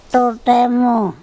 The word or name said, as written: ToTEmo